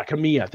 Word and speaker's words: Kamiya: Kamiya? – Yeah, Kamiya.